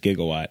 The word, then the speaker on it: gigawatt: But so did John Keister, and, Bill Nye was like there, and he goes, oh, actually it's pronounced gigawatt.